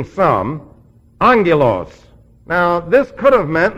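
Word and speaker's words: angelos (ἄγγελος): And angelos, by the way, double gammas in the Greek language simply indicate a diphthong, and the diphthong is pronounced NG. We transliterate it A-N-G-E-L.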